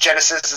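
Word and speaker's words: Genisys: It's pronounced Genesis. It's not spelled that way, though. It's spelled oddly.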